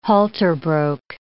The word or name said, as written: halter broke